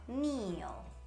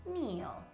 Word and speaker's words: kneel: in inglese si dice knee. È una parola che comincia con KN. Questa unione di due lettere, cioè K e N, mi raccomando, tutte le volte che lo trovate, ricordatevi di non pronunciare la K. Dimenticatevene, fate finta che non ci sia. Quindi ginocchio si dirà knee, inginocchiarsi si dirà kneel, kneel.